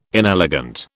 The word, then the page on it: inelegant: British English